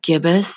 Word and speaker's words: gibbous: And today, Moon Day, June 1st, the waxing gibbous moon, actually gibbous moon, I somehow like to use the soft G instead of the hard G. So anything that that comes with the G, I would say I would use a soft G instead of hard G, but like fungi is actually fungi and gibbous is actually gibbous.